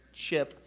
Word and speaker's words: Cip: By, by the way, all those who are looking at the slide and thinking I'm mispronouncing his name, it is Chip. You just don't see the H, right? It's, it's, it's hidden there.